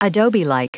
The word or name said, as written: adobelike